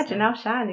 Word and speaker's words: Jennelle Shen: Yeah, Jennelle Shen.